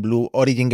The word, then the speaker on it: Blue Origin: A ver, desde que dejó Amazon, ya hemos dicho que ha pegado el acelerón Blue Origin, que por cierto, qué bien lo pronuncias. En este podcast somos de pronunciar muy mal las empresas. Estamos intentando pronunciar bien Agianspace. – Hostia. – ¿Vale? Porque solemos pronunciarlo, sobre todo yo, fatal. Pero lo de Blue Origin, con el acento bien puesto, me lo voy a apuntar.